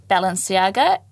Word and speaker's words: Balenciaga: You know, she's just signed a contract with Balenciaga, and sorry, I don't know how to say it in English.